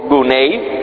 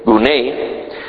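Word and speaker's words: gunei (γυνή): Está la palabra en griego gunei, que es la pronunciación correcta, G-U-N-E, gunei.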